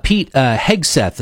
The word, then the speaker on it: Pete Hegseth: Pete Hegseth, is that how you pronounce it? – Yes, you're right.